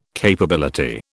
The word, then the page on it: capability: British English